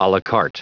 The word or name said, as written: alacarte